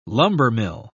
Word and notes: lumbermill: British English

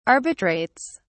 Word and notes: arbitrates: British English

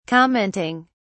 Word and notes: commenting: British English

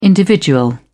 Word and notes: individual: British English

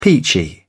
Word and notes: peachy: British English

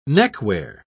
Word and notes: neckwear: British English

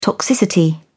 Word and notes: toxicity: American English

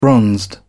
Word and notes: bronzed: British English

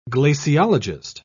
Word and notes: glaciologist: British English

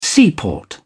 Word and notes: seaport: British English

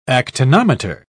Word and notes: actinometer: British English

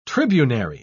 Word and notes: tribunary: British English

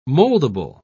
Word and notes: moldable: British English

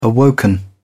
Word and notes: awoken: British English

